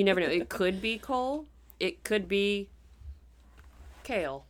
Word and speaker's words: Coel: One is, okay, these names are spelled C-O-E-L. Mm-hmm. I pronounce that Cole? Coel? Cole. We're going to say Cole to keep it simple.